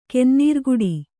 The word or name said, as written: ಕೆನ್ನೀರ್ಗುಡಿ